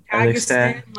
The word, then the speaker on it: Kazakhstan: Kazakhstan, like, 9-1.